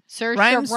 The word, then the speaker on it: Saoirse: Saoirse Ronan. – Rhymes with inertia. – Yes, the only reason I know that is because you named the title of that episode, Saoirse Rhymes with Inertia.